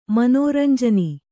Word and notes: ಮನೋರಂಜನಿ: manōranjani

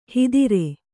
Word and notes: ಹಿದಿರೆ: hidire

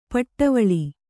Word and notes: ಪಡೆವಳ್ಳ: paḍevaḷḷa

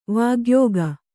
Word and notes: ವಾಗ್ಯೋಗ: vāgyōga

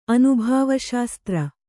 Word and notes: ಅನುಭಾವಶಾಸ್ತ್ರ: anubhāvaśastra

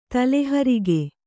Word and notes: ತಲೆಹರಿಗೆ: tale harige

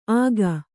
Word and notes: ಆಗ: āga

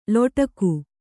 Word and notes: ಲೊಟಕು: loṭaku

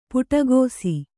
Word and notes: ಪಿಡಿಕೈಸು: piḍikaisu

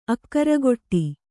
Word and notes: ಆಕಾರಗೆಡು: kannada

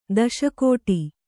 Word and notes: ದಾಸಗೌಡ: Kannada

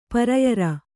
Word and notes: ಪರಿಯಾರ: priyāra

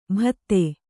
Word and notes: ಬಾಧೆ: bādhe

